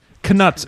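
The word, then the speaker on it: Knuts: Canuts. He's in the audiobook. He says canuts. That's what it is.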